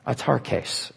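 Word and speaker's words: autarkes: The word content, atar case in Greek, it rhymes with guitar case.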